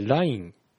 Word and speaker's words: LINE: まあこのネイバーにしてもちょっとイントネーション怪しいところはあるんですが、それは置いといて、まあ LINE ってまあローマ字で書いてあるんだから、まあ英語的な発音のが合ってんのかなって考えると、まあ頭にアクセントを持ってきて LINE っていうね、こっちが合ってんじゃないかなみたいな思いますね。